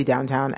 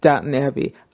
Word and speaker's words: Downton Abbey: I always want to say downtown, and I know that's so wrong. But Downton Abbey.